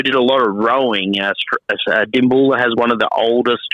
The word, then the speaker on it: Dimboola: So the name of the town is Dimboola. So Dimboola is D-I-M-B-O-O-L-A.